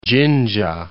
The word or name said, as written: Jinja